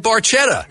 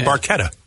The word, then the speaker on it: Barchetta: They called it Red Barchetta. – Right. Which is technically incorrect pronunciation. It's a Barchetta.